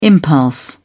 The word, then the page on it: impulse: British English